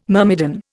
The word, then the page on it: myrmidon: Indian